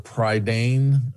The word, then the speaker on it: Prydain: You know something, look, it's the Chronicles of Prydain, I think. Is that how it's pronounced? – I believe so.